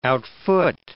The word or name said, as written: outfoot